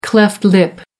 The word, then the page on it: cleft lip: American English